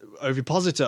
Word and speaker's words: ovipositor: Okay, so the thing we're going to talk about is the new fetish that's emerging from a brand of sexual toy called the Ovipasta. Now, this is a gentleman... Ovipositor, I beg your pardon.